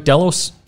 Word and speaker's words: Delos: Did I pronounce that right? Delos. – Delos, either way. Yep, that's great.